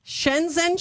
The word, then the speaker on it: Shenzhen: Shenzhen, China.